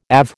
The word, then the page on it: AV: American English